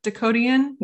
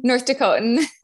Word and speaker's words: North Dakotan: Dakotian? – North Dakotan.